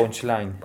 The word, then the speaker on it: punchline: Pero digamos, tú sí, el trap lo entiendes, según lo que entendí, es como a través de la rima, a través del line punch. – punch? El punchline. – Ok, lo dije mal.